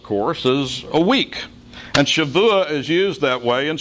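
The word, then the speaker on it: Shavua (שבוע): It is pronounced Shavua. Shavua.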